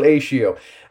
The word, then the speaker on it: Acio: No, it's Phil Acio. Basically, it's because it rhymes with fellatio.